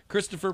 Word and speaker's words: Christoph: Christopher Waltz, Inglourious Bastards. Darren. – Christopher Waltz. – Yeah, I'm going to say that too, but you guys keep mispronouncing his name. He's Christoph.